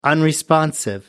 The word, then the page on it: unresponsive: American English